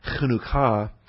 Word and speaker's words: Chanukah: and then the N-U-K-K-A-H, and that way you can rush ahead. You don't put the accent on the Chan. You can rush through that short syllable to the Chanuk, Chanukah, number 2597.